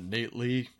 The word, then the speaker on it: Knate: I guess you pronounce it Nate Lee. It's K-N-A-T-E.